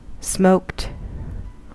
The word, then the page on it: smoked: American English